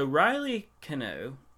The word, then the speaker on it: Riley Keough: So Riley Keough, is that how you say it? – Yeah.